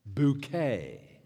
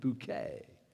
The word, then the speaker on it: Bucket: Oh, that's not the way Hyacinth pronounces her last name, Bucket. She insists on the French pronunciation, Bouquet. Hyacinth Bouquet.